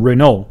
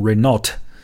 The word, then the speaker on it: Renault: 总之呢，你你把它说成 Renault，不会有人嘲笑你的。只要记住最后这个 t 是不发音的，不要把它读成 Renault 就行了。